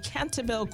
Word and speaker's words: Cantabile: Pronounced Cantabile. – Oh, what? What did I say? – You said Cantabile or something. – Okay, so how is Cantabile? – Yeah, it's like Latin for singing all together.